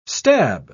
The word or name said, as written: stab